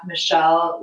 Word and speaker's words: Michelle: It's at Michelle with two L's.